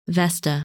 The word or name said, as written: Vesta